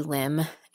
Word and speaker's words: Lim: So yeah, my name is Connie Kimberly Lim, and my artist name is MILCK. So my last name is spelled L-I-M, and my first two initials are C-K for Connie Kimberly, and I decided to scramble up my name.